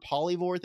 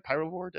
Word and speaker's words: Pyrovore: And we have a Biovore Polyvore thing. It's like that model needed an update badly. Did I say that right? Pyrovore? Did I say that correct? – You said Polyvore.